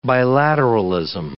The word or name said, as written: bilateralism